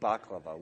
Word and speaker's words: baklava: Actually, that's how they say it in the original Greek, baklava.